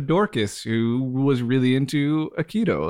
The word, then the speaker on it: aikido: At a very young age, he saw an old man doing aikido. Is that how you pronounce it? – Yeah, aikido.